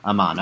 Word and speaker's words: Amon: Amon, okay.